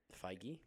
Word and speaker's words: Feige: Feige? – That's the one.